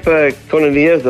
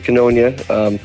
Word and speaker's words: Koinonia: Camp Koinonia, though all of us locally just mispronounce it Koinonia, is located up at Parry Sound.